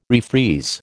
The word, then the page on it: refreeze: American English